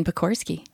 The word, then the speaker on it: Pokorski: Is that how you say your name? – Yeah. – Is it really? – Yeah. – Say it out loud. – Jen Pokorski.